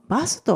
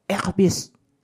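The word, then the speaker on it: Airbus: バスとは発音されないんですよね。えー、フランス語ですと、エアビス、エアビスになります。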